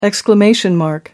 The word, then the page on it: exclamation point: American English